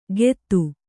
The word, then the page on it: ಕೆತ್ತು: kettu